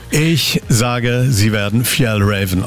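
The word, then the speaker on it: Fjällräven: Ich bin Franziska Köhler von der schwedischen Outdoor-Marke und es wird richtig ausgesprochen Fjell Raven. – Fjell Raven.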